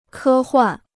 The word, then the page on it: 科幻: kē huàn